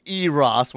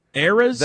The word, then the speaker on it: Eras: Please tell me Toby did not pronounce Eras as Eros when reading that tour name. – Eras? – The Eras tour, yeah. – Oh, like an era.